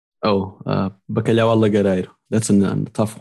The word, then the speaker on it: bacalhau: So bacalhau, which is the salted cod, the dry salted cod.